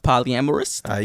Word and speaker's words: polyamorous: Polyamorous.